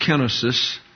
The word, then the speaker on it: kenosis: It's actually pronounced kenosis, and it has to do with not utilizing your divine attributes.